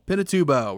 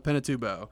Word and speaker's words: Pinatubo: Pinatubo. – We're going to go with Pinatubo.